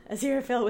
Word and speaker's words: Aziraphale: It drowned out everything else until it was all Aziraphale could feel. His whole being thrummed with it. It was so bright, incandescent, and radiant. Oh, Aziraphale whispered.